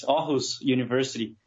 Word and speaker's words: Aarhus University: Have I pronounced it correctly? It's Aarhus University? – Yeah, thanks for having me. You're pretty well. It's Aarhus University.